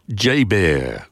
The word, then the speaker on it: JBER: The base is commonly known by the acronym JBER, pronounced J-Bear.